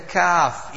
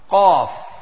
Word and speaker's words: kaf: So that's also the tongue, but it's not extreme back, and that's easy pronounced, kaf. Kaf is empty mouth, qaf, that's a full mouth, so kaf.